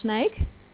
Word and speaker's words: Sneek: My center is in Holland, in Friesland, in the town called Sneek. And it's pronounced S-N-E-E-K.